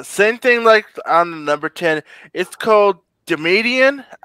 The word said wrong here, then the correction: Dominion: It almost sounds Dominion. It's pronounced Dominion.